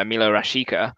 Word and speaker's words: Milot Rashica: Um, you know, Joe mentioned Werder Bremen, and one player who's really stood out for them is uh, Milot Rashica.